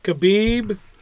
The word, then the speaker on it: Khabib: Khabib.